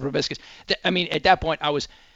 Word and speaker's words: proboscis: The proper pronunciation, I believe, is proboscis. Proboscis.